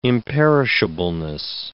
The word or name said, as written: imperishableness